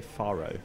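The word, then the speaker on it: Fårö: and they've gone to I want to say Faro, which is the sort of anglicized pronunciation of the island where Ingmar Bergman lived for most of his later life, in sort of rural seclusion. I think it's pronounced Furo.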